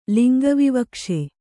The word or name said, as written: ಲಿಂಗವಿವಕ್ಷೆ